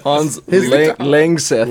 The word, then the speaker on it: Hans Langseth: The record of the longest beard ever recorded goes to a Norwegian-American man named Hans, and I can't pronounce that. – Hans Langseth. – Langseth. Honestly, I was going to say something else, but...